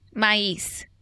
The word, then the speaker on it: maize: Maíz. – That's a Spanish pronunciation, but say it in English. – Corn. – No. Say that same word, but like if you were reading it as an English word. – Maíz? – No. How do you spell that? – M-A-I-Z? – E.